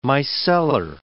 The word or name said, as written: micellar